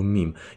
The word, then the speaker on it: meme: Ten se hodně často dostane i do těch uh, memes, Ty zase nevím, jestli je to meme nebo mím. Já se vždycky do toho tak zamotám, vlastně pak nevím, jak se to vyslovuje. Jak se to vyslovuje? No, mím? – Jako v češtině memečka, že jo? – Ale tak meme prostě.